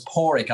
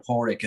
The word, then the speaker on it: Padraic: But it's Padraic. I always say a dog's paw and a rickshaw. So Padraic is the best way to say it.